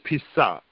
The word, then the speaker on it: Pesach: When we speak of the Passover in Hebrew, that word is Pesach.